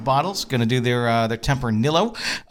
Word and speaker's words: Tempranillo: And the guide told us Tempranillo. – Like Amarillo.